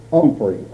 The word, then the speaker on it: Humphrey: Humphrey. – Humphrey. H-U-M-P-H-R-E-Y. – He pronounces it Humphrey. – H-U-M-P-H-R-E-Y. – But the H is silent. – Yeah, but I'm calling the dictating machine now for the transcriber. – H-U-M-P-H-R-Y.